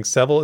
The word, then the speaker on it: Sevil: JN, my lore savior, put out a tweet saying Sevil is pronounced like devil. Just swap the D with an S.